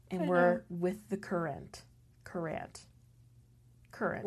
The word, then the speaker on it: Courant: And then the Hartford Courant. – Yes. – Which we've been pronouncing as Courant.